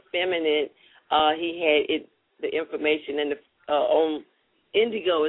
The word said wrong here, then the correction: Indiegogo: Is this how you pronounce it? And then another Indiegogo, right.